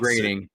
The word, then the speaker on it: Groening: Brooks, Grant, is it Groening or Groening? I can never pronounce Groening. – It is Groening.